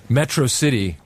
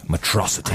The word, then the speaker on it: Metro City: Metro City is the city that we're vying for Metrosity. – The control. And I kept pronouncing it Metrosity.